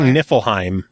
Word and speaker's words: Niflheim: I've been saying Niflheim. – Niflheim? – That's about right.